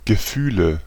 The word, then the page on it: feeling: /ˈfiː.lɪŋ/; American English; [ˈfiː.lɪŋ]